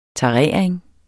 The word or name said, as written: tarering